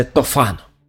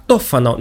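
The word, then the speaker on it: Tofano: В ней повествуется о богаче и ревнивце Тофано. Итальянцы произносят это имя как Тофано, но вы уж простите, я сохраню то произношение, к которому приучил меня Ерорд Масс, иначе говоря, третий участок, где мы обсуждали когда-то Декамерон с дядей Араиком.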